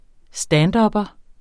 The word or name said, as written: standupper